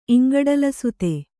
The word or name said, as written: ಇಂಗಡಲಸುತೆ